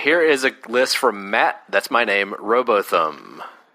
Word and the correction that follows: Robotham: Robotham. I've been saying it wrong the whole time. It rhymes with Gotham.